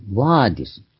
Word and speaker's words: vadis: ワーディス、 V-A-D-I-S。ラテン語ではブっていう発音ではなくてウなんですね。 U 前に言いましたが、クオーワーディス、ドミネ。ドミヌスが主、がという資格。ドミネ、呼びかけるの、ドミネになります。クォー、ワーディス、ドミネ。